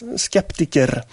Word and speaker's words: skeptiker: It's just pronounced slightly different. Skeptiker. It's a Greek loanword.